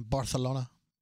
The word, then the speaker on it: Barcelona: But this one's in Barcelona.